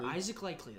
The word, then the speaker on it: Isaac Likely: Yeah, It was an impressive win for Oklahoma State without Cade Cunningham and Isaac Likely. Am I pronouncing that correctly? – Isaac Likely. That is correct, sir.